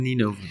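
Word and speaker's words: Ninove: And then, is that what it's, that's how you pronounce it? Ninoven? – Ninoven, yeah.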